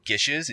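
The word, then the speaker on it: geis: You see it spelled gios, because oftentimes in pop culture, there's things called gioses, which is, it's pronounced gish. It took me a long time to figure that out because I saw, like, why is it pronounced like fish with a G?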